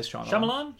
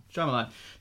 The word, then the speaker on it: Shyamalan: How do you pronounce him? I say Shyamalan. – Shyamalan.